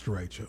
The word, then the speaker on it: derecho: Yeah, I'm pretty sure it's derecho.